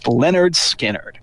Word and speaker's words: Lynyrd Skynyrd: It's pronounced Lynyrd Skynyrd,